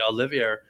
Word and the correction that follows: Charles Oliveira: Charles Oliveira. – Oliveira, thank you.